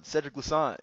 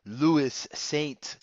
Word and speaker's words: Louissaint: Yeah. Pretty straight on. Cedric Louissaint. Yeah, most people usually butcher it and say Louissaint.